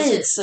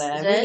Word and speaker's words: Yeats: Men Yeats kan vi. – Yeats uttalar man det. Yeats.